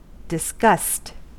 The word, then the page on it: disgust: American English